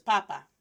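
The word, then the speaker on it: papá: P-A-P-A. And then the second time again, P-A-P-A. But let's put an accent over the second A in the second word. All right? So the first one, sin acento, without the accent, is pronounced as papa.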